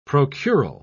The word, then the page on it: procural: British English